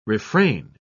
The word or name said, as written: refrain